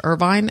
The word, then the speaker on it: Irvine: Um, Paul is the owner of Paul Dash Irvine. Am I saying your last name correctly? – It's Irvin, silent E.